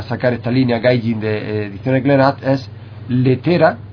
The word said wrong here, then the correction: Lettre: No sé si lo pronuncia bien, porque tiene una especie de capuchito encima de la E. – Lettre.